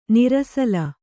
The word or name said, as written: ನಿರಸಲ